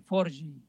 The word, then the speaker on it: Forge: F-O-R-G-E, Forgi. – F-O-R-E, ¿R qué? – G-E.